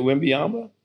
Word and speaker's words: Wembanyama: Isn't it Wembanyama? – Oh, Wembanyama.